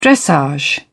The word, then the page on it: dressage: British English